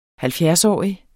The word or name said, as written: halvfjerdsårig